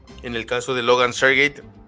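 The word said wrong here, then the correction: Sargeant: eh, eh, Sargent,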